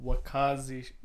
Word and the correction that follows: Wakizashi: Wakizashi. Wakizashi. It's a smaller.